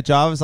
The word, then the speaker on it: Jervis: Yeah, some say Jarvis. I say Jervis. – Yeah, I don't love it. – It's spelled J-E-R-V-I-S.